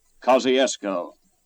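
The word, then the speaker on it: Kosciusko: He spelled it one way, we another, and it's pronounced a third way. Kosciuszko, Mississippi.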